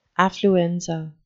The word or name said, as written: affluenza